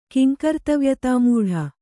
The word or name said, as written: ಕಿಂಕರ್ತವ್ಯತಾಮೂಢ